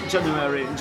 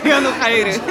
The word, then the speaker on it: Januhairy: Bo teraz to jest jakiś mega ogromny trend, że dopiero było to January. Tak coś... chyba to się wymawia? – Tak. – Więc to się pisze Januhairy. – Januhairy. – Januhairy. Januhairy, tak.